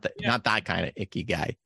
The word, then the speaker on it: Ikigai: And we've had different types of conversations related to this, but your Ikigai, did I say it right? – Yeah.